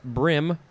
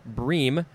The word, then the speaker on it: bream: Next is, it could be pronounced a couple ways, brim or bream, B-R-E-A-M, first form, noun from the 14th century.